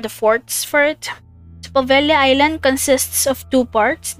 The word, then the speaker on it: Poveglia: I searched how to say this, and I've heard some people call it the Poveglia Island, and I heard Poveglia Island, so I searched how Italian people pronounce this word, and it's Poveglia, so I'm going to use Poveglia for its pronunciation.